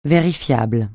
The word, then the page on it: vérifiable: francais